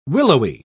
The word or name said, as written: willowy